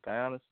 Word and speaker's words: Giannis: Giannis? – Yeah.